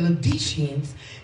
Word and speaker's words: Laodiceans: I'm going to start over because he just gave me the pronunciation. Okay, Lord. And unto the angel of the church of the Laodiceans. That's what he said. Laodiceans. Because the O is silent.